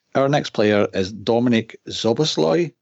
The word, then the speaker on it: Szoboszlai: Szoboszlai, I think, is the pronunciation I got. I asked a Hungarian journalist that I follow on Twitter, I asked him for a pronunciation. I think it was Szoboszlai.